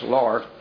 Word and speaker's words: Lard: I'll flip between Laird and Lard. I guess it's the way it's spelled, it's Lard.